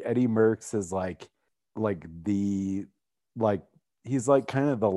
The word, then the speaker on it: Merckx: The final topic, is Eddie Merckx. If I'm pronouncing that right. Am I pronouncing that right? – Merckx. Yeah.